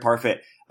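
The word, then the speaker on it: Parfit: You addressed the repugnant conclusion that your mentor, how do you pronounce it, Parfait? – Derek Parfait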